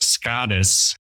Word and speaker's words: SKÅDIS: It's now it's pronounced SKÅDIS. It's a piece of IKEA furniture, S K A with the circle above it, D I S,